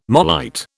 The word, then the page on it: marlite: British English